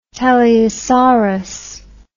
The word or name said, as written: teleosaurus